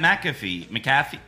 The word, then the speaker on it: McAfee: Like he wouldn't even interact with Pat McAfee. How the fuck do you say his name? – McAfee. – McAfee. Okay.